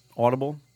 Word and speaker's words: Audible: Audible? – No, you said it right, but wait.